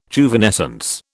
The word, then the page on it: Juvenescence: British English